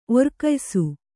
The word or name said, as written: ಒರ್ಕೆಯ್ಸು